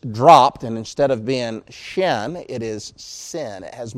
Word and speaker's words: shin: If that diacritical dot is located over the right prong, it has the sh sound to it, like she, shin.